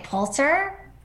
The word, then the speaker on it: Pulitzer: people sometimes mispronounce the first syllable of this prize as pule, but it's really pull. – Okay, Poulter? – Helen?